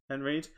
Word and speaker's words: Henreid: Is that how you say his name? Paul Henreid? – Henreid.